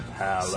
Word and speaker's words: PALAVR: Hey, you said it right. – Yeah, I've been working on it. I've been practicing. – Palaver. Said right, but it's spelled wrong.